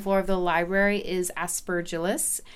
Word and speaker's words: aspergillus: And because the mold is aspergillus. – Aspergillus. We cannot guarantee that the pronunciation of aspergillus. – That. Aspergillus. – Aspergillus. It is called aspergillus.